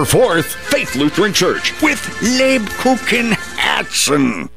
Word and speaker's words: Lebkuchen Herzen: Lubkuchen Herzen. – The Kupenhergens Herzen. – Lubkuchen Herzen. – Nine! Lebkuchen Herzen!